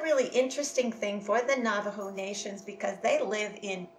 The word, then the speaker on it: Navajo: It's N-A-V-A-J-O, but the J is pronounced as an H, Navajo.